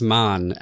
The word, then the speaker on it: Mann: The book that saved my life is called The Magic Mountain by Thomas Mann. – German pronunciation. I've been saying man, but yeah, he is German. So Thomas Mann.